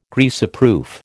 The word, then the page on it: Greaseproof: American English